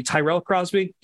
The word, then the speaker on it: Tyrell Crosby: Yeah, I keep going back between Tyrell and Terrell. I never know which way I'm supposed to inflect on the Y. So Tyrell Crosby had left the Lions.